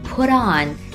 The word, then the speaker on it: put on: 细心的听众们可能已经发现了，在美式英语中 put on 在连起来说的时候，中间的字母 T 的发音接近于字母 D，只是大家不要把字母 D 发得很重，轻轻地来一下就可以了。